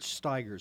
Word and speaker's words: Steiger: his name is Robert Steiger, spelled like Tigers with an S in the front of it, and he is unbelievable.